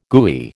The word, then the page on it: gooey: American English